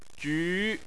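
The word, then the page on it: ju: tone 2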